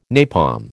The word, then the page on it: napalm: American English